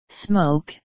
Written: smoke

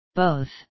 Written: both

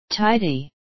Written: tidy

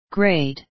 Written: grade

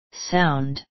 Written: sound

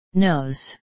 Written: nose

